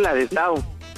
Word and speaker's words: Ladislao: Es Ladislao. – ¿Con E o con L? ¿Cómo está? – L. – A ver, Lanislao. Por eso, lo dije bien, ¿no? – Ladislao. – Ladislao. Ladis. Ladis no. Con D. Ladis. Ladislao.